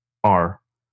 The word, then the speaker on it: Nostr: And also I saw the beginnings of Nostr. Or Nostr, I'm not sure how you pronounce that. It's Nostr.